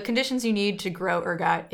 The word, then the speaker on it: ergot: So that is the ergot poisoning theory, which also came out in the 70s. But basically their theory is that there was this fungus called ergot that was... – Can you spell that? – E-R-G-O-T.